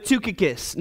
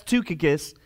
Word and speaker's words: Tychicus: So let's start with Tychicus. Now, it starts with a Y, but it's pronounced with a U. That's Tychicus.